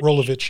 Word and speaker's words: Rolovich: Rolovich? – Rolovich, yeah.